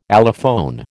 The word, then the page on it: allophone: American English